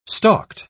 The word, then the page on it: stalked: British English